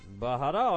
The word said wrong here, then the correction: Mahara: on the mound. No, Mahara. I'm sorry. I've been pronouncing that wrong all along. Mahara on the mound for the Fukuoka SoftBank Hawks.